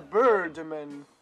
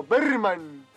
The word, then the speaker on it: Birdman: Porque en realidad se pronuncia Birdman. Birdman. Y yo digo Berman. Nada que ver.